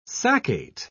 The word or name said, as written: saccate